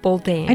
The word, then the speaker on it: Buldain: Buldain.